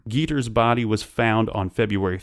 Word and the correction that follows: Jeter: It's pronounced Jeter. – Oh, thank you. – It's just like Derek Jeter. – Like Derek Jeter, but spelled differently.